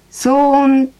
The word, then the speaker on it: 騒音: あ、それはですね、騒音って読むんですよ。